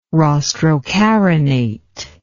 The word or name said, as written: rostrocarinate